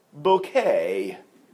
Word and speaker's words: Bucket: Her surname is Bucket, but she pronounces it Bouquet.